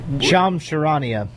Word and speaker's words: Charania: Adrian Wojnarowski and Shams, how do you say his last name? – Shams Charania. – Yeah, there. We'll go with Charania.